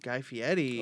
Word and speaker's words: Guy Fieri: Guy Fieri. – Oh, yes. You pronounced it properly too.